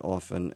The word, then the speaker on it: often: The difference between saying O-F-T-E-N, pronouncing it often and often.